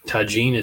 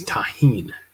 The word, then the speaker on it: tagine: It's not tagine, it's tahine.